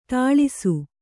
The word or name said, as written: ಠಾಳಿಸು